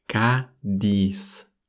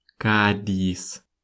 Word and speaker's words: Кадис: Но город звучит как Кадис. Кадис. Вот это сочетание «к» и следом за ним идущее «ди» для русской фонетики — это, видимо, что-то невозможное для произношения именно со звуком «и». И поэтому мы трансформируем звук «и» в звук «э».